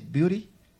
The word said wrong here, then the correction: Beatty: Warren Beatty. – Beatty, ¿cómo se dice, Ana María? Beatty.